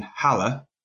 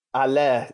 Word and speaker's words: Haller: I never realised that the West Ham forward, who I thought was called Sebastian Haller, is called Allaire, yeah, silent H.